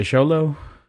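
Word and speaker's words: Xolo: Zolo, that's how you say his name, right? – Yeah, Sholo.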